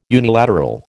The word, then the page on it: unilateral: American English